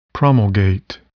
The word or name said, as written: promulgate